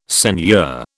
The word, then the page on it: seigneur: British English